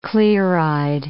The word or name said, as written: clear-eyed